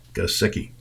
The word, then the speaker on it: Gesicki: Gesicki.